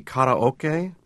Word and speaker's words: karaoke: Well, it's actually karaoke, and which is a Japanese term that combines the words empty and orchestra.